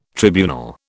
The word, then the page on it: tribunal: British English